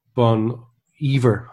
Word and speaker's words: Bon Iver: um uh, First of all, I had Bon Iver, I think it's pronounced, yeah.